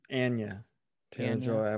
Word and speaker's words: Anya Taylor-Joy: Anya Taylor-Joy, I've learned is how it's pronounced.